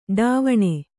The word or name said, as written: ಟವಣೆ